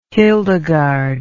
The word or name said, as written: hildegarde